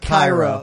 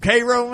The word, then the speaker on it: Cairo: Spelled not like Cairo. – Cairo. – Spelled right. – like Cairo, but not pronounced Cairo.